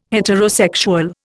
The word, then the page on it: heterosexual: Indian